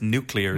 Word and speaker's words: nuclear: I don't think I said nuclear right there. It's nuclear. – No, you didn't. It was great. It was great. – Nuclear. – Nuclear, it's pronounced.